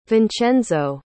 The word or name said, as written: vincenzo